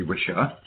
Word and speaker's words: Wicca: Wicca was a really useful term. We don't even pronounce it right with two C's. It's an Anglo-Saxon word, so it should be Wisha or Wisha, depending on your gender.